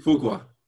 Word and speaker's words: Fuqua: Also, I think if they had that Antoine, how do you pronounce his last name? Fuqua? – Fuqua.